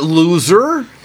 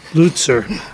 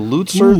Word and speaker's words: Lutzer: Erwin W. Luce, what's it? – Not loser. – Lutzer. – Yeah, no reason to hide. Right. Erwin W. Lutzer, ooh. That's how I pronounce it. – Yep. – I don't know. – No, that sounds good.